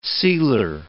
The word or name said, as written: sealer